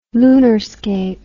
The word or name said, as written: lunarscape